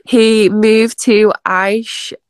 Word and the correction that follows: Ayrshire: I don't know how to pronounce it. It's A-Y-R-S-H-I-R-E. – Ayrshire, thank you.